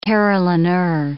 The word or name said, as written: carillonneur